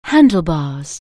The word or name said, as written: handlebars